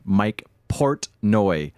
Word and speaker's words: Portnoy: Well, I always have to slow down when I say his name. I always like to say it, Mike Portnoy.